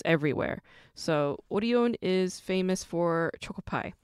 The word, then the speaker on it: Orion: Very similar to Lotte Entertainment and CJ Entertainment, Showbox comes from a food manufacturing company called Orion, or you might pronounce it as Orion, but Orion is how it's pronounced in Korean.